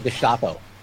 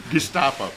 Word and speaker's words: Gestapo: Or using the term- he says we shouldn't call them Nazis while pronouncing Gestapo in the German. – Oh, right. Yeah. – Gestapo.